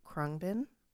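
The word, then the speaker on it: Khruangbin: But they kind of bring this like soothing, calm vibe, almost like Krungbin. – Yes. – I always butcher their name. – No, you said that really perfectly.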